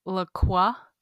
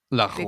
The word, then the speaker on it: LaCroix: And they will pronounce it LaCroix. – LaCroix. – Because that's the correct way, apparently, to pronounce it.